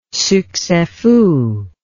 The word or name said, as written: succes fou